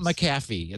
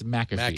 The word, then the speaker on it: McAfee: It's not McAfee. It's McAfee. – McAfee. – Yes. – By the way, and it's just weird. He does a video on how to uninstall McAfee.